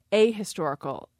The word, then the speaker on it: historical: The issue with historical is that the stress has moved to the second syllable. And in unstressed syllables, that H sometimes is lightly pronounced if it's pronounced at all. So for speakers with a lightly pronounced H, they will say an historical. – So it's an H light is what you're saying? – An H light. My H is pretty well pronounced on historical, so it makes sense for me to say a historical event.